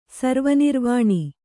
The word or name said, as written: ಸರ್ವನಿರ್ವಾಣಿ